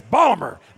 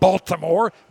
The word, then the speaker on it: Baltimore: Pronounce it correctly. It's Baltimore, not Baltimore. Baltimore. Do it like a local.